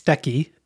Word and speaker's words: Steky: This is the, I believe it's pronounced Stecky, S-T-E-K-Y, model.